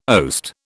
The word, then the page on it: oast: British English